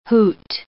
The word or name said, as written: hoot